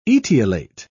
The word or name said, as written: etiolate